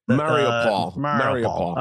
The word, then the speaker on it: Mariupol: Mariupol.